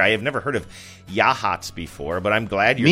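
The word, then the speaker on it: Yachats: I'm just going to try to do a yakety yak, but say Yahats, and that fell apart. It looks like it would be pronounced – it's like Y-A-C-H – maybe ATS, but it is in fact Yahats.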